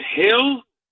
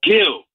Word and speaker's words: Gil: In reference to your pitcher from the Yankees, you guys keep calling him Hill. The guy's Dominican. It's pronounced Gil. – Why do the announcers pronounce it heel? I'm going to always go off of Susan Waltman because she goes and asks these players how they pronounce their names. – I get that. But the reality is, it's Luis Gil, yeah, not heel.